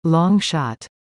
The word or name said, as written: Long shot